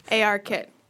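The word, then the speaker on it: ARKit: What do you pronounce it? Arc Kit or AR Kit? – AR Kit.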